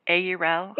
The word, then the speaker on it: Aurel: Aurel. – Yes, I'm doing the AU as in AU, and you're doing it as an O.